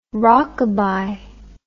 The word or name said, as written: rockaby